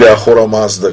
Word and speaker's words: Ахура Мазда: Армуст или Ахура Мазда, как правильно произносится на древнеиранском языке, Ахура Мазда,